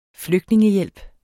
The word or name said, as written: flygtningehjælp